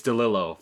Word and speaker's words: DeLillo: Dad, what are you, DeLillo? – It's DeLillo, for sure. Okay. We've conquered the Pynchon Pinecone debate and the Proust. – Yep. – And then, but for, Don DeLillo, because the two L's at the end, and I read it, I've watched a couple of interviews and stuff.